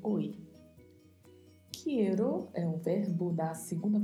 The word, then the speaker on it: quiero: Cuidado para não deixar de pronunciar a letra I. – Pronuncie corretamente a letra E e a letra O também mais fechadas, então pronunciamos quiero. Agradecer-te.